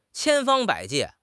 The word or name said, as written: 千方百计